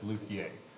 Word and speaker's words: luthier: Luthier. – Luthier. – Yeah, now if you're French, the pronunciation is Luthier.